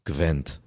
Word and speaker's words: Gwent: Gwent.